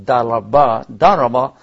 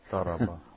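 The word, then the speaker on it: daraba (ضرب): The term is used wadribahuna, and this is referring to feminine, or in the case of this word daraba is first of all, it's difficult to pronounce if you're not an Arab because it has the ba in it. – Daraba.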